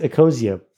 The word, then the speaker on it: Ecosia: Is it pronounced Ecosia? – It is pronounced Ecosia, but you can say it any way you want.